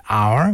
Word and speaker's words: hour: Example, the letter H in hour, we don't pronounce H.